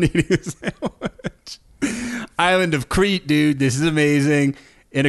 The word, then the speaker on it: Crete: Wait, did he say crate or did he say Crete? – He said Crete. – Okay. – That was me mispronouncing it. I'm the idiot.